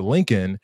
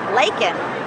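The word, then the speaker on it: Laken: It's easy to read. Lakin. But he said Lincoln Riley.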